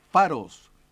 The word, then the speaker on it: Pharos: Su denominación proviene del nombre de la isla Faros, y se escribe P-H-A-R-O-S, Faros, ubicada frente a Alejandría.